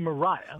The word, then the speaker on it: Maria: Now, the team, if I had to pick a team, and I sort of feel like I have to, it was Maria Mitchell's team. Let's talk about Maria Mitchell. – Yes. Well, and I should say she pronounced her name Mariah.